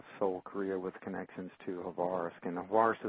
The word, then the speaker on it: Khabarovsk: Khabarovsk is how that's pronounced in Russian.